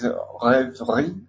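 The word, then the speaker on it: Rêverie: Pero es difícil de pronunciar, es Reverie de Debussy, que la traducción es más o menos como ensoñación, podría decirse.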